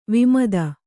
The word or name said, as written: ವಿಮದ